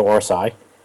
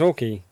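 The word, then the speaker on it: Orci: I kind of touched upon this, but the two writers, so Robert, I'm not sure if his name is pronounced Orki or Orsi. – I think it's Orki.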